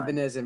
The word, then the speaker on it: Epenesa: Is that how you say it? Epenesa. – He had a shit combine. – Epenesa, my fault.